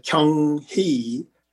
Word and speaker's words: Kyung-hee: And my university name is Kyung-hee University. That's the correct pronunciation.